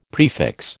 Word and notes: prefix: American English

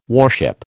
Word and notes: warship: American English

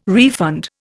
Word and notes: refund: Indian

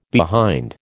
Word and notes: behind: American English

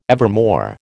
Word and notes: evermore: American English

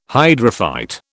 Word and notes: hydrophyte: British English

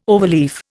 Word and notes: overleaf: Indian

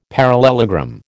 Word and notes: parallelogram: American English

